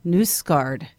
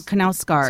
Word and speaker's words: Knausgaard: I feel like ending it there, but I would be remiss not to correct an earlier mispronunciation of last week. I think we talked about on the podcast, who I will now call Nusgaard, Carl Ove Nusgaard, not Knausgaard. – So it's not the hard K.